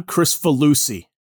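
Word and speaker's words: Kricfalusi: I think it's John Crisfalusi. – That's it. I think you nailed it.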